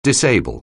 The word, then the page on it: disable: anglais